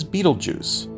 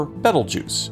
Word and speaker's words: Betelgeuse: The bright red star forming Orion's upper left shoulder is known as Betelgeuse, or Betelgeuse, if you want an alternate but equally valid pronunciation.